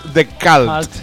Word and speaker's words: The Cult: Llevo diciendo The Cult como un ceporro toda la puta vida. Y es The Cult.